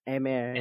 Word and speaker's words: Aimer: Aimer, is that how it's pronounced? Aimer. – Aimer, yeah. Aimer. French. Aimer.